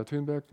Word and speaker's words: Thunberg: Das ist Greta Thunberg. Oder Greta Thunberg, ein bisschen schwedisch ausgesprochen.